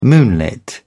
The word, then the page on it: moonlit: British English